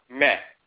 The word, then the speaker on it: ma'a: Coptic, the late version of the language, the last dialectical version of the language, the term ma'a is often spelled meh. So ma'a is pronounced meh. The word love in ancient Kemet is pronounced mar or meh. In Coptic, it's also pronounced meh, like M-E.